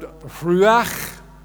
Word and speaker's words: ruach: The word ruach. Can you say ruach? Ruach. Can you say ruach? Ruach. In English, it's spelled R-U-A-C-H.